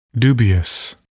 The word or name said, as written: dubious